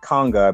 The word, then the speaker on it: Kanga: You said Congo? It's like Conga, I believe. K-A-N-G-A. – Spell it for me one more time. – K-A-N-G-A.